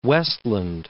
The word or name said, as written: Westland